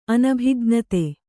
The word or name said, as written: ಅನಭಿಜ್ಞತೆ